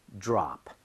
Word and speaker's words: drop: 그리고 드랍이 아니라 드롭도 안 되고 DR이 충돌하면서 지읒 비슷하게 발음돼서요. 이렇게 따라해 주세요. drop, drop.